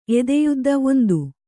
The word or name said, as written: ಎದೆಯುದ್ದ